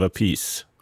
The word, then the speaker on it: Appice: Och en gång sa Rod att everybody wants a piece of Appice. Okej. Okay. Då är det så vi ska uttala det. A piece, alltså Carmine Appice.